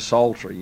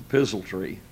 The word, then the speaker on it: psaltery: he doesn't pronounce it right, calls it the pizzle tree.